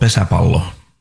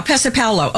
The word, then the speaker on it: pesäpallo: It's called Pesäpallo. – Yeah, Pesäpallo.